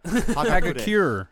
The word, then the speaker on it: Hagakure: Correct me if I'm wrong, if I mispronounced that. – Hagakure. – But, we're speaking English, so it's okay, but it's a, yeah. – Hagakure. Hagakure is correct how you say it.